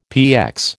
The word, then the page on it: PX: American English